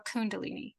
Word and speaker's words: Kundalini: But the question I have for you is, explain Kundalini to, did I say that right? Kundalini or Kundalini? – Kundalini.